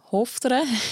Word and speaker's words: Hochdorf: Sie ist 33 und sie wird sich in Hochdorf im Kanton Luzern sein und die Einheimischen sagen Hofter, wenn sie richtig äh, aussprechen, einbürgern lassen.